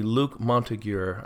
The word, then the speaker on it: Luc Montagnier: So I'm trying to pronounce his name correctly. Luc Montagnier, I believe, is how he pronounces it.